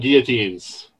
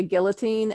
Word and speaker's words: guillotine: It bothers me every time I hear somebody say a guillotine, and I'm like, guillotine.